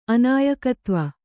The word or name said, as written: ಅನಾಯಕತ್ವ